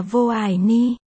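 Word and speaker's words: vô ai ni (我爱你): Tiếng Trung đọc là vô ai ni.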